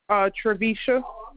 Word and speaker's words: Travisha: Travisha. – Can you spell it for me? – T-R-A, V as in Victor, I-S-H-A.